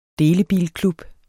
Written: delebilklub

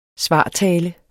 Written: svartale

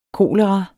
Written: kolera

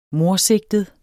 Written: mordsigtet

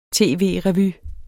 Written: tv-revy

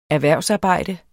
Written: erhvervsarbejde

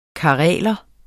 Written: kareler

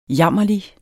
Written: jammerlig

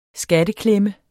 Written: skatteklemme

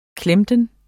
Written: klemten

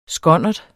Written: skonnert

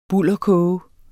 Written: bulderkoge

